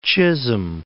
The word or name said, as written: Chisholm Trail